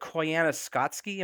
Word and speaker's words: Koyaanisqatsi: Is it Koyaanisqatsi? Am I saying it right? – Yes.